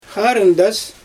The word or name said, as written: xarɯndas